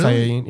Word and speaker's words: Saiyajin: Saiyajin, yeah. That's how you say it. – That's so fucking weird. – In Japanese and Spanish.